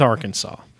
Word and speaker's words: Arkansas: It starts with Arkansas.